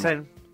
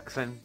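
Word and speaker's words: czerń: ¿Cómo se dice negro en polaco? Vamos. – Xern. – Ah, Xern. Con Z, Xern. – Xern. – Y con acento en la N.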